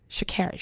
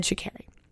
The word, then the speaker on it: Sha'Carri: Sha'Carri, oh, I don't want to say her name wrong because she literally fucking corrected the interviewer at the Olympics. Sha'Carri. She said Sha'Carri.